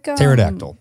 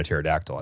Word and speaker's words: pterodactyl: Like when the pterodactyl was around, it wasn't like we were calling it a pterodactyl, I don't think, right? – Right. No, some idiot afterward was like, hey, you know, it'd be fun. – Pretty cool if we did. – Put a silent P.